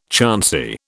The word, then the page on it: chancy: British English